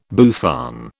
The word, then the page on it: bouffant: British English